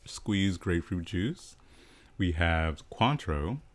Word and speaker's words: Cointreau: That's how you say it? – Yeah. – I say Cointreau. No, not kidding. I say Cointreau. – No. – Or Cointreau. – Yeah, like, gotcha. It's like French pronunciation.